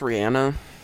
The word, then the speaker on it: Rihanna: Apparently it's pronounced Rihanna. I just found out like 30 minutes ago. – I thought we used to say Rihanna and then they said, no, it's Rihanna.